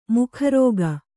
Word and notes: ಮುಖರೋಗ: mukha rōga

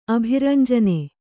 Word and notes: ಅಭಿರಂಜನೆ: abhirañjane

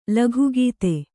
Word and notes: ಲೋಕಗತಿ: kannada

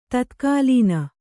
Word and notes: ತತ್ಕಾಲೀನ: Kannada

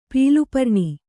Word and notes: ಪೀಲುಪರ್ಣಿ: pīlu parṇi

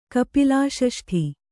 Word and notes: ಕಪಿಲಾಷಷ್ಠಿ: kapilāṣaṣṭhi

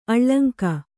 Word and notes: ಅಳಂಕು: kannada

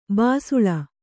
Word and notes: ಬಿಸಿಲ್: kannada

